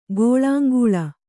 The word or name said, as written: ಗೋಳಾಂಗೂಳ